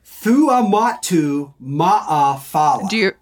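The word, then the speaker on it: Fuamatu-Ma'afala: Thua Matu Ma'a Fala is how you say it.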